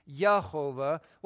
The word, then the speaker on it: Jehovah: There isn't a J sound in Hebrew. So it's not Jehovah. If you were to use that series of vowels, it would be Yehovah.